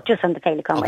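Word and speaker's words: Feileacáin: So if people want to help out, by the way, they can go to felicon.ie. So now that's spelled F-E-I-L-E-A-C-A-I-N.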